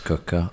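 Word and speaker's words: Quooker: This podcast is brought to you by Cooker, spelt Cooker, pronounced Cooker, 100 degree boiling hot water directly to your kitchen tap.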